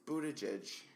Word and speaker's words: Buttigieg: Buttigieg. I think I just said it. – Yeah. – Buttigieg.